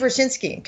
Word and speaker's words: Rochinski: Is, it's, it's, Steve Roskinkowski, R O C H I N C K I. I think okay. it's no. I'm sorry, Steve Rochinski. Gosh, okay. please don't tell him I mispronounced his name.